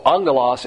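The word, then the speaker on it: angelos (ἄγγελος): So angelos, A-G-G, in the Greek, it's pronounced, the two double G's are pronounced with an N.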